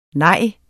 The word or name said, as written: nej